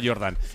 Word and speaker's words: Jordan: que se escribe Jordan, pero como Michael Jordan, ¿no? Úrsula Jordan.